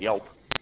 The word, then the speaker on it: Yelp: The thing when you click help, it starts up this thing called YELP, which is how help is pronounced in Swedish.